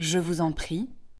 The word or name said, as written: je vous en prie